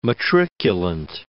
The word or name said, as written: matriculant